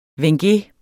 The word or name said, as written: WENGE